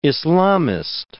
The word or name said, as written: Islamist